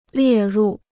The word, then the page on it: 列入: liè rù